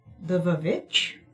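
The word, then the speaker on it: The VVitch: And today we watched The Vavitch, yeah. – Which I guess we're supposed to call The Witch.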